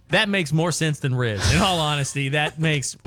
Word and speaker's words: Rhys: It's spelled R-H-Y-S. – R-H-Y-S? – Yes, ma'am. – Riz? – Is that Reese?